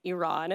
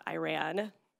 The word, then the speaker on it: Iran: So it is pronounced Iran. It is not Iran.